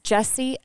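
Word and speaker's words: Jessie: So Instagram is Jesse L.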